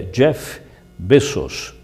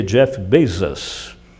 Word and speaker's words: Bezos: Jeff Bezos, pronunciado em espanhol. Ou pronunciado em inglês seria Jeff Bezos.